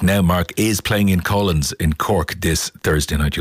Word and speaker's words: Coughlans: You'll find out more at, and I will spell it for you because I would have pronounced it Coughlins, C-O-U-G-H. – No, that's what you do, brother. – C-O-U-G-H-L-A-N-S.ie.